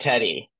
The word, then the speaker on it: Teti: Whenever I see it written out, I'm like, is it Titi or you tell me what? How do you pronounce it? – Oh, you're giving it a little bit of extra sauce. Like you're churching it up. It's just Teti.